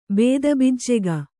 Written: ಬೇದಬಿಜ್ಜೆಗ

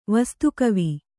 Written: ವಸ್ತುಕವಿ

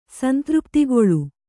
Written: ಸಂತೃಪ್ತಿಗೊಳ್ಳು

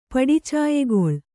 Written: ಪಡಿಚಾಯೆಗೊಳ್

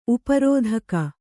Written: ಉಪರೋಧಕ

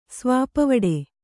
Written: ಸ್ವಾಪವಡೆ

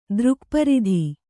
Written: ದೃಕ್ಪರಿಧಿ

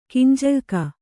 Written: ಗಂಜಳಿಕೆ

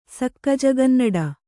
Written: ಸಕ್ಕಜಗನ್ನಡ

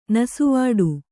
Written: ನಸುವಾಡು

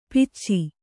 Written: ಪಿಚ್ಚಿ

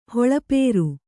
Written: ಹೊಳಪೇರು

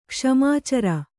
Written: ಕ್ಷ್ಮಾಚರ

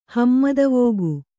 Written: ಹಮ್ಮದವೋಗು

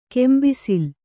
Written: ಕೆಂಬಿಸಿಲ್

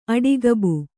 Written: ಆಡುಗಬ್ಬ

